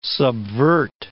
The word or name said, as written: subvert